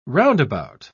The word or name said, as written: roundabout